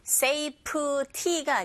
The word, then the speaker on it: safety: Safety가 아니라 safe, safety, safety. 앞에 강세 꽝 주시고요.